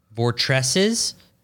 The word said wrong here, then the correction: vortices: Vortices, yeah.